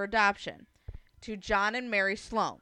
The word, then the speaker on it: Sloan: Schlong? – Sloan. – Schlow. – Slow. – Schlow. – There you go. – S-C-H-L-O. – S-L-O-A-N. But you got to pronounce Sloan. – It differently. Yeah. – Sloan. – Yeah. – I thought you said slow.